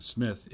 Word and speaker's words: Psmith: Psmith is uh, spelled the P is silent, uh, so uh, P S M I T H.